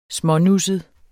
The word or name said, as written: smånusset